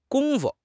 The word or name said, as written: gong1 fu5